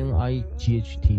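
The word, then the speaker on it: knight: 所以我在这边排了一个表格啊啊啊，在这个地方啊，C C C C H C K L 打个 dash 的话啊，就代表说 C H 可以发出 ch，rarely 也可以 K 啊，C K 一定是 k 啊，K 也发出 k，K N 呢，像 K N I G H T night 啊，它 K 是不发音的时候，我打三个星啊，所以也不见得你看到 K 就一定要念 K 啊，就是 K N 你念 N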